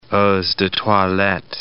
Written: eaux de toilette

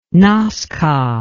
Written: nazca

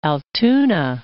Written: Altoona

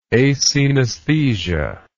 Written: acenesthesia

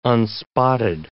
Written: unspotted

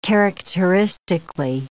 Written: characteristically

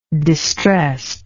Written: distressedness

